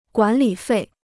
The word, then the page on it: 管理费: guǎn lǐ fèi